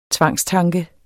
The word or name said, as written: tvangstanke